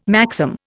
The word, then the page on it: maxim: Indian